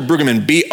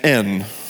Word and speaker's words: Brueggemann: Walter Brueggemann, Brueggemann. So almost like you'd want to spell it.